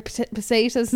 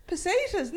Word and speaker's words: pesetas: Pesetas. No, that's not how you say it. – Pesetas.